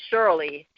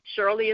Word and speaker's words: Shirley: It's Shirley. Shirley is Shirley.